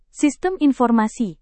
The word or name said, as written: sistem informasi